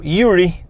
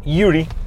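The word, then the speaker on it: Ury: Creo que uno de ellos era de apellido Uri, o Yuri, así es la pronunciación, Yuri.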